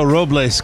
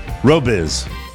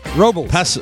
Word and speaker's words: Robles: Did I say that right? You gave me a pronouncer. – Robiz. – Robles. – What is it? – Robles. – Robles? – The Americanization Robles. Is Robles. – Robles. I don't need a pronouncer for Robles. – Okay. – Then. Because it's not Robiz. – Robles. – Robles. – Paso Robles. Paso Robles. Oh, it's BLZ, not BIZ. – Yeah, BLZ. Robles.